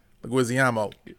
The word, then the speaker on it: Leguizamo: How do you pronounce his last name? – John Leguizamo. – Leguizamo. Are you sure? – He said it himself.